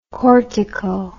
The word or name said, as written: cortico